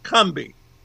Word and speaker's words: Combahee: We forget that even after this, after the river raid, in down south, it's pronounced Cumbie. But it's a raid on the Combahee Ferry. Doing it the way the Yankees might have done it. It's C-O-M-B-H-E-E Ferry, actually.